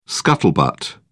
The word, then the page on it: scuttlebutt: British English